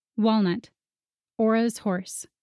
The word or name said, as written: Walnut